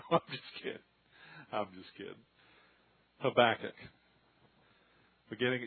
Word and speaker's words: Habakkuk: It's on page 1241, Habakkuk. That's the American pronunciation of it. The way the Hebrews would say it would be Habakkuk, real fast like that. So I'm going to slow down and use the Texas pronunciation, which is Habakkuk.